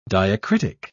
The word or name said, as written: diacritic